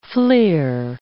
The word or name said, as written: fleer